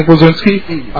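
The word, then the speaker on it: Glisczinski: Hi, I'm John Glazinski.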